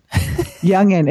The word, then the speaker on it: Jungian: It's like the young and the restless. A lot of people call him Jung, Carl Jung. He's a Swiss psychologist. – Yeah. – But it's actually pronounced with a Y instead Jungian. – Of a J.